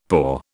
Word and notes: poor: British English

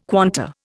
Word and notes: quanta: Indian

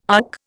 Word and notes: UC: Indian

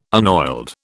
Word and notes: unoiled: British English

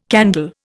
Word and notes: candle: Indian

